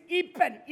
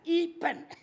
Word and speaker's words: Ipen: My name is Ipen. You know pen, you know pen? You write with pen? Okay, remember pen. And start E with us, E pen.